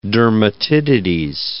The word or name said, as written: dermatitides